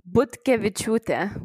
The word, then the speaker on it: Butkeviciute: So it's Butkeviciute, which is a very tricky one.